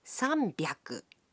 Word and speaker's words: sanbyaku: But the pronunciation can be a bit tricky. 300 is pronounced sanbyaku. 600 becomes ropyaku. And 800 is pronounced happyaku.